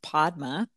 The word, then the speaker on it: Padma: So the name, which I pronounce Padma, is a Sanskrit name. It's a name, Sanskrit is an ancient language from India. And Padma means lotus, like a lotus flower.